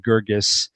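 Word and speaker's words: Guirgis: Gurgis. And by the way, that is the way to pronounce it, Gurgis, even though I just did it wrong for a moment.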